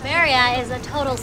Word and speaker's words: birria: I want to say it right. I'm so bad with birria. – Birria. – Birria. – Birria. – Birria. – Yes.